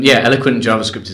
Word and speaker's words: Eloquent JavaScript: Because the elephant JavaScript is very good. Yeah. – Elephant. I didn't realize how easy it is to mispronounce that word.